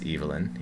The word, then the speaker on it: Evelyn: It is pronounced Evelyn. He was British.